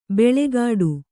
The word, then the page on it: ಬೆಳೆಗಾಡು: beḷegāḍu